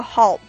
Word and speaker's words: halt: 还有一个大家应该注意一下的词，就是这个 halt，这个 halt 啊就是暂停、中断的意思。它的这个发音呢，在一个这个元口 r 后边有一个 l，元音后边出现这个 l 呢，就应该发成一个舌侧音 u。